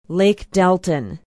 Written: lake delton

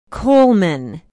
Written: coleman

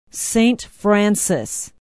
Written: saint francis

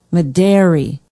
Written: medary

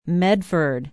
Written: medford

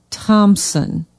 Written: thompson

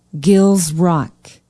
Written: Gills Rock

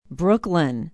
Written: brooklyn

